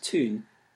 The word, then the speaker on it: town: While consonant sounds have remained relatively stable, vowels have changed more radically, although often in predictable patterns, so that we need only learn that the word town was pronounced tune to be able to correctly pronounce down, mouse, house, and so on.